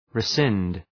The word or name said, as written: rescind